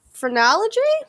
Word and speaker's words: phrenology: Laszlo got his degree in phrenology? Is that the right pronunciation of that? – Yes.